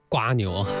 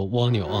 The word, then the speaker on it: 蜗牛: 我不知道这次周杰伦在温州如果再唱起这首歌的话，会不会还叫他瓜牛，还是说会回归到他本来的一个读音，应该叫做蜗牛啊。